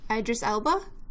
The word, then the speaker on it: Idris Elba: Oh man, I always mispronounce his name. Idris Elba? Is that how you say it? – Yes.